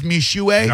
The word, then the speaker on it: Mixue: And I say all that because if you have a, they give the phonetic pronunciation, Mishue. Have you heard of Mishue? – No. – It is spelled Mixue. M-I-X-S-U-E. Mixue, but it's pronounced, I'm sorry, M-I-X-U-E. No S, M-I-X-U-E, and it is pronounced Mishue.